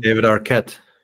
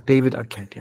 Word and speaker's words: David Arquette: David Arquette. – David Arquette, ja.